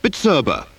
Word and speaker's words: Bizerba: Before we tell you about a range of high-quality precision weighing machines, slicers and price labellers, we'd like to give you a short lesson in pronunciation. The manufacturer of the finest high-tech weighing, slicing and price labelling machinery is Bizerba. B-I-Z-E-R-B-A. Pronounced Bizerba. So now you can pronounce it.